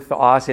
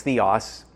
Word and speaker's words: theos: You might notice when I say the word theos, and in some pronunciation systems, it's theos. Pronunciation system I learned is it's theos. It sounds a bit like the word theology.